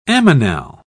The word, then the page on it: ammonal: British English